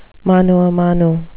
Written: mano a mano

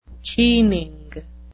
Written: keening

